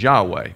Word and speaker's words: Yahweh: The German approximation was Yahweh, no, Jehovah. The more modern approximation is Yahweh, but it's been lost.